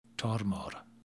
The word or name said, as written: tormore